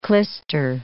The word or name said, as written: klister